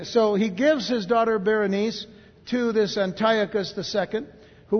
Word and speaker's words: Berenice: Ptolemy the Second Philadelphus, who was the king of Egypt from 285 to 246 BC, he would give his daughter Berenice, B-E-R-E-N-I-C-E, like Berry Nice, but Berenice is the way it's pronounced.